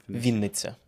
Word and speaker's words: Vinnytsia: Vinnytsia.